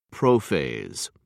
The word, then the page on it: prophase: American English